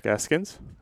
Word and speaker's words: Eskins: Good buddy Eric Eskins.